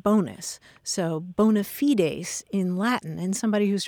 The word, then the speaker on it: bona fides: It was borrowed intact from Latin, and it's spelled the same way, B-O-N-A, and then a separate word, F-I-D-E-S. And in Latin, you pronounce it bona fides, and that literally means good faith.